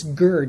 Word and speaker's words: gur: It's gur. G-U-R is the way we would spell it, I guess, in English.